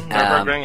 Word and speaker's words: Nürburgring: The only course I really remember in any game is one in Ray Tracer and one in Forza, which is the Nürburgring, if I even said that right. – Yes, no, you got it right. Well done, Nürburgring. Yeah.